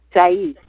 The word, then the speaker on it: Shai: The You called it a sheik or something like that? Shai? Shai, thank you. Yes. – Okay. You can spell that S-H-A-I. – S-H-A-I, Shai.